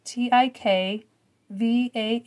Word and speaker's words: tikvah: And it was the Hebrew word for rope, and it was spelled T I K V A H, and it's pronounced tikva.